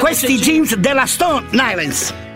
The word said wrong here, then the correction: Stone Island: Stone Island. – Ma perché scrivono? Scrivono come si legge. – Si chiama così, Stone Island.